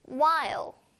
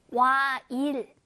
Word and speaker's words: while: while 와일 아니고요. 일하고 막 입을 막 잔뜩 찢어가지고 하면 큰일 나고요. 와 한 다음에 ill 하고 혀를 안쪽으로 굴려볼까요?